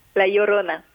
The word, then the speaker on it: La Llorona: Est-ce que la Lorana, est-ce que je le prononce bien C'est qu'est-ce que ça veut dire la Llorona.